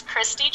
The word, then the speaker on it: Christi: So it's Christi Jaynes. C-H-R-I-S-T-I. I still have to spell that for people. It's Jaynes, J-A-Y-N-E-S, at Christi Jaynes on Twitter, Instagram, and Facebook.